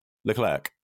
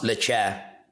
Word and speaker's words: Leclerc: Leclerc. – Charles Leclerc.